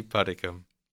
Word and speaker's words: Puddicombe: Andy Puddicombe.